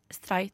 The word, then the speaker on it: Stray: Dette var altså del én av FrPs historie, og del to skal du høre etter dette, hvor nå skal høre Einar Stray er det sånn du uttaler det? – Stray, tror jeg man sier.